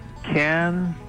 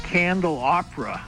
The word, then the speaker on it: candelabra: I think it's candelabra. – You've got it. That's exactly right. Can dill lob to lob a ball. And uncooked is raw. So can dill lob raw or candelabra. – That made me think, like, I always talked about a candle opera. And that's how I always called it, a candle opera. And now I realize that I wasn't saying it correctly.